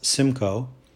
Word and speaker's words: SMCO: The Simcoe Group, pronounced Simcoe, S-M-C-O is the acronym, pronounced Simcoe, will be made up of owners of more than three stores.